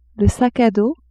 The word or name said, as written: sac à dos